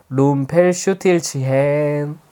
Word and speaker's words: 룸펠슈틸츠헨: 룸펠슈틸츠헨 해봐. – 룸펠슈틸츠헨.